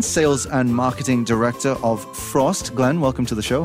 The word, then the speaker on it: Frozt: It's spelled F-O-R-Z-T, but it's pronounced Frozt.